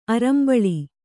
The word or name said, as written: ಅರಂಬಳಿ